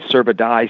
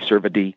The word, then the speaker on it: cervidae: Pronounced in several ways, cervidae, cervidae.